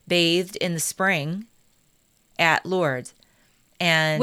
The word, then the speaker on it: Lourdes: I say Lourdes, even though I shouldn't. I know you don't pronounce the S, but the village in France.